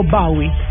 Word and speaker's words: Bowie: Murió David Bowie, eh, o Bowie en una mejor pronunciación, eh, un cantante británico que por supuesto pues ícono de la música en el mundo.